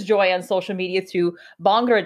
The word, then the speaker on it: Bhangra: So it's pronounced as Bhangra.